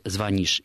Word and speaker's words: звонишь: Но, еще раз подчеркну, до тех пор, пока жив последний образованный человек, который помнит, что нужно говорить «звонишь» и «включит», это будет единственно правильными акцентологическими вариантами.